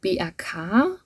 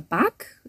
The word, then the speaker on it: BAK: Ein weiterer Tipp ist äh, BAK oder BAK, äh, Basis für aktuelle Kunst. Ein bisschen niederländischer noch ausgesprochen.